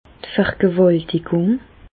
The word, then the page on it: d' Vergewàltigùng: Bas Rhin; Reichshoffen